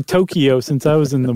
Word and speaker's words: Tokyo: Or I should say Tokyo. I've been saying it wrong. – Oh, it's not Tokyo. – Tokyo, it's Tokyo. Tokyo.